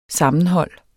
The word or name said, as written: sammenhold